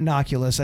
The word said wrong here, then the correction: Oculus: I said it wrong, but you know what I'm talking about.